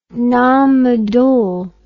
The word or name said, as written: Nan Madol